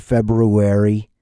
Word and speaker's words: February: Don't ask me to pronounce that R. I'm not calling it February. I will get the R in library. Let's not call that library, but we can call it February. I'll happily call it February. I'm not worried about pronouncing that R.